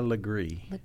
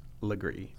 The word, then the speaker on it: Legare: We were talking about the, you know, common mispronunciations of South Carolina names, and Julia's last name is spelled L-E-G-A-R-E, but in South Carolina, that's pronounced Legree. – Legree.